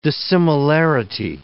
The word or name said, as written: dissimilarity